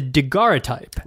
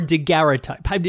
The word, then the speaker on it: daguerreotype: daguerreotype. – Right. So, or daguerreotype. Is there a correct pronunciation here? – There's multiple ways to say it, and they said it in many different ways back then, in fact.